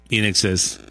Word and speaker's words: INXS: No, pues así se llama, o como INXS, así le digo. – Ajá, In Excess, pues In Excess. Ajá. ¿Cómo se pronuncia? In Excess.